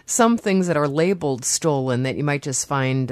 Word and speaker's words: Stollen: Well, Stollen is how you say it here. It's over in Germany, it's pronounced Stollen.